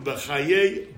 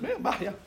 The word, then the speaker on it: Bachya: So, the Rabbeinu Bachaye, Bachya, some pronounce, how do you pronounce it? – Bachya.